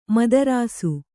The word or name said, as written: ಮುದ್ರಸ